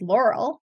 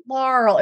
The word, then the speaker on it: Laurel: Now I go by Kate because when I moved from Ohio to North Carolina, I didn't like the way they pronounced my name. It was like Laurel. It was very like a Southern draw on it.